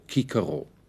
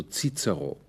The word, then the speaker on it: Cicero: Die Römer der republikanischen Zeit sprachen C stets wie K, also Kikero. Doch schon in der Spätantike ging man bei C vor E und I zur Aussprache Z über, also Cicero.